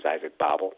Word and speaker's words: Babel: But Isaac Babel, for me, and I think I'm always pronouncing his name wrong. I've been corrected. I believe it's called, his name's Isaac Babel.